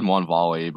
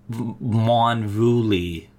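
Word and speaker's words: Mwonvuli: I always said Mwanvali, but I think that was wrong. – There's a U, Mwanvuli Recluse.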